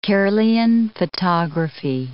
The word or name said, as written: Kirlian photography